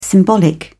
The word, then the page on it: symbolic: British English